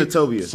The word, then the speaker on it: Senatobia: Richard Chandler from Senatobia, am I saying it right? – Yes, Mississippi. – Senatobia.